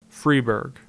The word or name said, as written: Freeburg